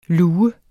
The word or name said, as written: lue